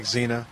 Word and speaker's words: Xena: It's spelled Xena, X-E-N-A. – Sounds like Xena. – Yeah, Xena.